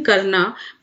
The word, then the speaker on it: Karna: When Karn was born, it's written Karna, but it's pronounced Karn. There's a sound na in that, which is very Indian.